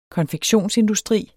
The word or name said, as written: konfektionsindustri